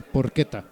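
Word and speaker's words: Porchetta: fui a un lugar que se llama Porcheta. No es cierto, Porqueta. Se escribe Porcheta con doble T.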